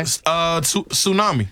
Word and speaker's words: tsunami: This has a silent letter in it. – Okay. – Tsunami. – Tsunami. – Tsunami. – Yeah. – Tsunami. – Sure does. – Now, some people might say tsunami. – Tsunami. – Tsunami. Somebody might say that. They'd be wrong.